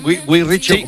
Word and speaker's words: Guy Ritchie: Lo he dicho bien, Guy Ritchie.